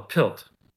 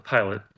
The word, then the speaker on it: PILT: All right, and in lieu of that, what they have is called a PILT. And this stuff you may be all familiar with. Some people pronounce it pilot, but it's payment in lieu of tax.